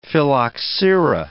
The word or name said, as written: phylloxera